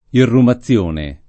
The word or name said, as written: irrumazione